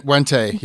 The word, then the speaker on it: Wente: And I don't know about the editorial practices, though, because Margaret Wente, am I pronouncing her name right? – Wente, yeah.